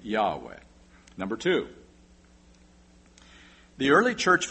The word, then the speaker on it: Yahweh: They were well aware of this term in the Hebrew Old Testament, and they wrote it in Greek as I-A-O-U-E, and if you pronounce it, it's Yahweh.